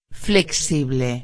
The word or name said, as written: flexible